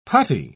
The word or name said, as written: putty